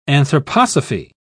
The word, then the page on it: anthroposophy: British English